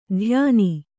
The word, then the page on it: ಧ್ಯಾನಿ: dhyāni